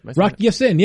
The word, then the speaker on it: Ya-Sin: Is that how you pronounce that? – Rock Ya-Sin, yeah.